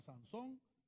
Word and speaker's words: Sansón: Sansón, acento en la O.